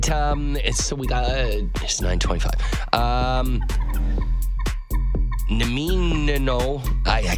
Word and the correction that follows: Nanaimo: Nanomi bars or Nanaimo, that's it. But they screw up the M and N.